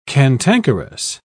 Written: cantankerous

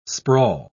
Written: sprawl

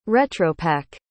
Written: retropack

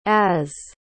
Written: az

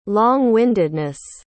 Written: long-windedness

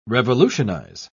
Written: revolutionize

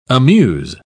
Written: amuse

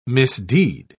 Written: misdeed

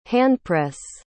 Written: handpress

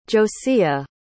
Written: josea

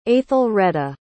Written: aethelreda

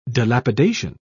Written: dilapidation

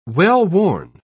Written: well-worn

